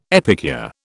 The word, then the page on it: epicure: British English